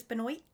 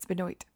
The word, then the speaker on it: Benoit: Chris Benoit? – Benoit. You said it wrong. It's Benoit.